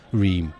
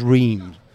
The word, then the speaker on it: Reims: He played for Reims, which is R-E-I-M-S, that's the connect. We could ask Eric how you actually pronounce Reims. Everyone says Reims, but yeah. Apparently in French it's Reims. – I think it is Reims.